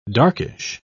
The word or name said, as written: darkish